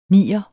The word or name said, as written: nier